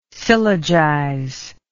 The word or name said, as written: syllogize